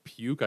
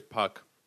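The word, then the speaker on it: Puk: I mean, a lot of people have probably been very much baffled by that name, but I have a harder time pronouncing Puck, P-U-K. I think it's Puck. What else would it be, right? Puke? I guess it could be Puke. That would be unfortunate. I don't think it's Puke. I'm pretty sure it's Puck.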